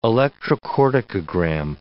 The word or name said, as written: electrocorticogram